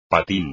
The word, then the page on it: patín: espagnol